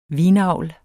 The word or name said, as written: vinavl